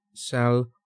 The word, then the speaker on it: Salvete: It says, it's spelled S-A-L-V-E-T-E, okay. But it says pronounced salute.